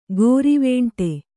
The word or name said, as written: ಗೋರಿವೇಂಟೆ